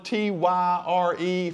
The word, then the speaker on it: Tyre: Now, Tyre is spelled T Y R E from in Red Bank, South Carolina, it's pronounced Tyre. So if you've got a different pronunciation, that's fine.